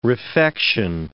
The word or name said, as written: refection